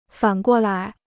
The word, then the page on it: 反过来: fǎn guo lái